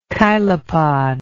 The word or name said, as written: chilopodous